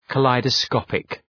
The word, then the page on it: kaleidoscopic: kə,laıdə’skɒpık